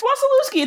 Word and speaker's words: Wasilewski: It's Wasilewski.